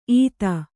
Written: ಈತ

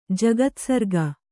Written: ಜಗತ್ಸರ್ಗ

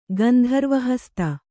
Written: ಗಂಧರ್ವಹಸ್ತ

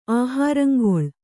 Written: ಆಹಾರಂಗೊಳ್

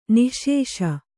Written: ನಿಶ್ಶೇಷ

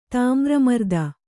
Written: ತಾಮ್ರಮರ್ದನ

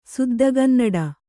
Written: ಸುದ್ದಗನ್ನಡ

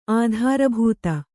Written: ಆಧಾರಭೂತ

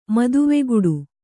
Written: ಮದುವೆಗುಡು

